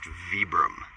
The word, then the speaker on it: Vibram: It's pronounced Vibram.